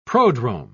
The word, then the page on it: prodrome: British English